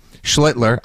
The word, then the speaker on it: Schlittler: Like, there were a lot of Sox fans there, and that was great, and that was a 1-0 game because Schlittler, I pronounced it correctly so you guys don't have to worry about hitting the dump button.